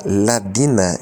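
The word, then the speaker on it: Ladina (الذين): Aladina, a palavra seguinte, Aladina, a seguinte palavra, Aladina, a qual se pronuncia Ladina neste contexto, é simplesmente o plural do pronome relativo Aladi, que significa quem.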